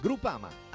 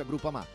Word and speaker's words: Groupama: Groupama, ah no, si pronuncia Groupama, di Paolo Felicetti.